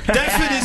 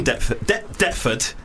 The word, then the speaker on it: Deptford: Deptford is a fucking annoying word because it's got a silent P in it, and I only figured that out like a week ago.